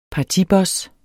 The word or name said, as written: partiboss